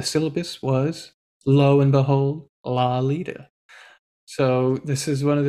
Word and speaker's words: Lolita: Oh, Lolita, as in lollipop. That's how it's pronounced.